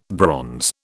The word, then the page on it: bronze: British English